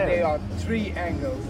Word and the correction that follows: triangles: I have to say they're triangles. – A triangle, yeah, of course. Okay. – It's a pronunciation thing, but it is quite important. Yeah. Otherwise it's the angle of a tree, which is really weird.